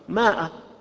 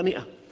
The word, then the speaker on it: مئة: لفظ مئة، مئة في المصحف بإثبات الألف، هذه الألف زائدة لا تلفظ، وتؤدي أحيانا إلى إشكال عند بعض العامة فيقرأها ماء، وهذا يعني خطأ شائع، هي مئة.